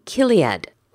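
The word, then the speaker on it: chiliad: Now that's actually spelt C-H but pronounced with like a K or a C. C-H-I-L-I-A-D. Kiliad.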